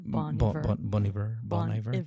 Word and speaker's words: Bon Iver: Bon Iver? – Bon Iver.